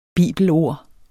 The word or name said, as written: bibelord